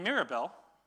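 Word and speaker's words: Mirabel: And it's no accident that her name, Mirabelle, rhymes with the word, miracle.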